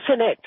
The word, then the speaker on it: Zenet: Eh, es cenet. – está acentuado con la, en la primera sílaba porque viene del árabe.